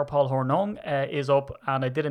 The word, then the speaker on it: Hornung: I said Hornung because I'm Irish. So I don't know. Is it Hornung or Horning? I know it's pronounced in the States Horning, but is that right?